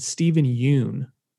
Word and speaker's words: Steven Yeun: And then we know the cast, which includes Daniel Kaluuya, Kiki Palmer, and Steven Yeun.